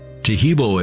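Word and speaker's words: Taheebo: Tejibo is spelled T-A-H-E-E-B, like boy, O, then the word tea, and then the word club.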